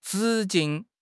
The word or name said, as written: 直径